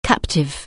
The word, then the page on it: captive: anglais